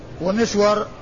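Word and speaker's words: مِسْوَر: ومسور بالميم المكسورة والسين الساكنة والواو المفتوحة.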